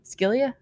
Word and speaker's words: Scylla: How would you say that? – Scylla.